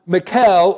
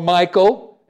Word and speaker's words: Michal: Now, Michal, or Michael, and you can pronounce this name in a variety of different ways, Michal in the Hebrew, or Michael in the Greek.